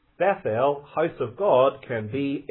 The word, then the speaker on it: Bethel: And as I intimated in the reading of our text, house of God is pronounced in the Hebrew Bethel. Bethel. The word Beth in Hebrew means house. And El, you may already know this one, means God. So Bethel means house of God.